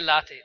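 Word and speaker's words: Lahti: Kan du uttala skådespelerskans namn? Christine... – Hon heter Christine Latty. – Ja, det är också så här jobbigt, precis som LeMay, man bara, exakt hur ska jag uttala det? Man vet inte riktigt, ja. – Nej, men den här skådespelerskan då, Christina Latty, Lady, whatever. – Det är Latty.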